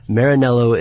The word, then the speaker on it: Maranello: It is made in a little town called Maranello. Oh, I'm butchering this so bad. – That's all right. – I guess it's my turn. – Sure, go ahead. You take it. – Maranello, Italy.